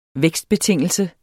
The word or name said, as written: vækstbetingelse